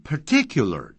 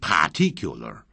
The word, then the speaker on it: particular: 但 particular 这个字啊，不要念成 particular， particular， per 是稍微弱一点点。好，不要念成 par。